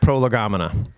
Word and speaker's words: Prolegomena: Title of the night, Prolegomena. Some of you are like, oh, that's how you say it. Some of you are like, what is prolegomena? It's from two Greek words put together. Pro, meaning first or before, and legain, which is to speak or words or to talk.